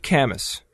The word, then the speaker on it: Camus: Camus. I know. I knew I was going to mispronounce it. They actually have the pronunciation here is Camus. I don't know why it's saying that, but I didn't say that at least. – Camus? – Yes.